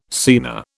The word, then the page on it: scena: British English